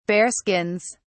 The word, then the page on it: bearskins: British English